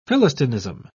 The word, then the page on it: philistinism: British English